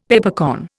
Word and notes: Peppercorn: Indian

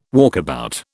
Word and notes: walkabout: British English

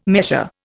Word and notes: measure: Indian

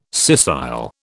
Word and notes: scissile: British English

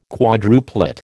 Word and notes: quadruplet: American English

